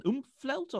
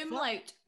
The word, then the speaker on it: umlaut: Is it called an oomphlaut or oomphlaut? – Umlaut. Umlaut.